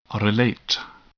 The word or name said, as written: relate